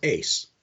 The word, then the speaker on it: Uys: And I had to ask how to pronounce his last name because it's spelled U-Y-S. And I had to ask people who actually spoke Dutch. It's like, oh, it's pronounced Ace.